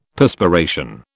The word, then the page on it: Perspiration: British English